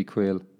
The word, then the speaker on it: Quaile: But it's spelled Q-U-A-I-L-E. Kieran Quayle. Must be Quayle.